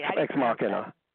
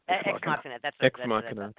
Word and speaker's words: Ex Machina: Is it Ex Machina or Ex, as Joe said, a machine? – Ex Machina. – Ex Machina. That's what I thought.